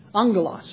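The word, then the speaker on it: Angelos (ἄγγελος): That's how the Greek word is basically pronounced. Angelos, or angel.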